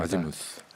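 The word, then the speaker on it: Azymuth: 그래서 애지무스라고도 이렇게 약간 바꿔서 말하는데 보통 이제 아지무스라고 되는데요. 노마 윈스턴이라는 영국 재즈 보컬리스트가 있었던 재즈 트리오가 또 아지무스가 있습니다.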